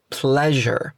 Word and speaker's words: pleasure: La pronunciación correcta es pleasure. Repite conmigo, pleasure. Pleasure. Pleasure significa placer. Entonces, it's a pleasure quiere decir es un placer. Fíjate que la S en pleasure suena un poco como si fuera una Y, suave.